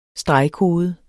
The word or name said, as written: STREGKODE